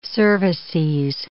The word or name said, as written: cervices